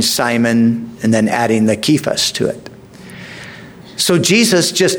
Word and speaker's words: Cephas: You shall be called Cephas," is the way to pronounce it, which is translated a stone.